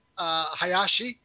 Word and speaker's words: Hayashi: So he already chimed in, but I'm introducing a member of the club here, Stuart Hayashi.